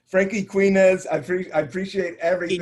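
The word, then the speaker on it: Quiñones: Yeah, Quinones.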